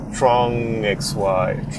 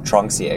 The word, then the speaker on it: Tronxy: Or is it Tronxy or is it TrongXY? – TrongXY1? Something like that. I call it Tronxy X1. – I think most people pronounce it Tronxy.